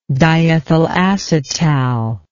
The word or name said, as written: diethyl acetal